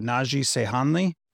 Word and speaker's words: Naci Seyhanli: We've got Naci, Seyhanli.